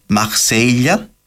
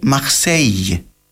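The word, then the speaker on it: Marseille: Το ίδιο ισχύει και για πάρα πολλές άλλες γαλλικές λέξεις, όπως τη λέξη για τη Μασσαλία που προφέρετουν κάποτε μαχσέιλια, ενώ σήμερα προφέρεται μαχσέιγ.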